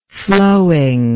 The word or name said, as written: flowing